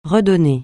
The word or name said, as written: redonner